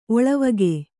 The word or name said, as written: ಒಳವುಗೈ